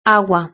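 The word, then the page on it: agua: Spain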